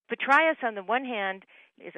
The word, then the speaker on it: Petraeus: Professor King said Petraeus, which is how the ancient Greeks would have said that combo of A and E. Petraeus, Petraeus, either way, the name was common in northern Greece, especially in Thessaly, says King.